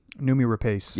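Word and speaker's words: Noomi Rapace: Numi? – Numi Rapace.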